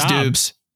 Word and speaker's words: Doubs: How do you say his name? Is it Doubs? I thought it was Doubs. – It is Doubs.